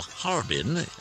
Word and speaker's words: Harbin: Harbin, or Harbin, as Neil prefers it pronounced.